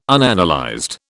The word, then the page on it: Unanalyzed: British English